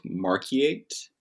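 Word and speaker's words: Markyate: Let's talk about St. Albans Psalter, which was owned by Christina of Marquiate.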